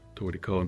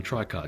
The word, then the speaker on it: tricot: But in Japanese, they write it toriko, katakana, toriko, and people call them tricot because that's what the name looks like. T-R-I-C-O-T, tricot. And I'm correcting people all the time. Toriko. Look, I know Japanese. That's how they spell it in Japanese. Toriko. That's the name of the band, Toriko.